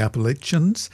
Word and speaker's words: Appalachian: But I have an old, old Irish friend who moved to the foothills of the Appalachians some 30 years ago, and we still keep in touch. And he tells me it's definitely pronounced Appalachian. So unless you know better, you stick with that one.